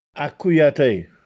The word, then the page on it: Accuiatè: Castellanetano